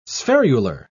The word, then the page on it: spherular: British English